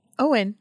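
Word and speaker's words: Eoin: How would you pronounce this? E-I-O-N for a first name. Ian? – Owen. – Owen? – That's Owen.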